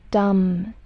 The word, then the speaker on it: dumb: And dumb, dumb. There is no need for you to pronounce the letter B. Dumb, not dumb, dumb, no, dumb.